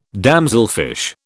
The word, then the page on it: damselfish: British English